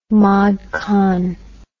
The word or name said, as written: mod con